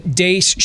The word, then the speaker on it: Deace: Follow us on Twitter at Steve Dace Show. Last name is spelled D-E-A-C-E.